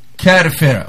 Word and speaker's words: Kafir: Kafir. – Vous allongez le A, il y a le K, le alif, donc un bâton, K, A, Calif. – Le F, okay. Et le R. Kafir. – Kafir, ok.